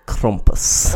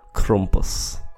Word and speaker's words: Krampus: I could say it like what I've heard actual German people say it like. – Say it. – Krampus. – Krampus. – Or I could just say Krampus.